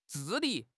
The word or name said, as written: 治理